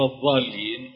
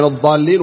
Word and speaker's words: الضالين: موضوع الضاد صعب نطقه مع إنه إحنا لغة الضاد. – صحيح. – فينطق ضاد. الأخ الله يجزاه الخير يمكن قال ولا الضالين غير المغضوب عليهم ولا الضالين. هي طبعا ضاد مغضوب عليهم ولا الضالين. فهذا خطأ شائع حتى عند الأئمة يعني.